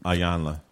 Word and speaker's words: Iyanla: Iyanla.